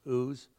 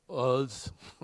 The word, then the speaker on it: Uz: Job is a man that lives in the land of, I think it's pronounced Uz. Uz.